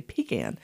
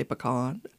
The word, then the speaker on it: pecan: It's one of those words that how you pronounce it sort of depends on the impression you want to give. If you want to sound a little pretentious or educated, you'll say pecan. And if you want to sound down home and country, you're going to say pecan.